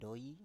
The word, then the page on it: doi: Biak